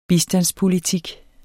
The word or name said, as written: bistandspolitik